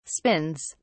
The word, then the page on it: spins: British English